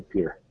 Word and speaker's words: Pierre: Correctly, because it's spelled Pierre, but it's definitely Pierre.